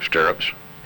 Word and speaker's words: stirrups: Stirrups, which rhymes with syrup, you know, which means you better stick to it, you know.